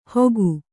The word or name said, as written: ಹೋಗು